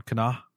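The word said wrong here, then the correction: Kena: Kenna, Kenna in September.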